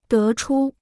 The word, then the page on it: 得出: dé chū